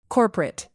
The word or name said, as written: corporate